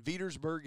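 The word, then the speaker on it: Veedersburg: Yeah, you know, and Fountain Central is located in Vetersburg, Indiana, correct? That's the pronunciation.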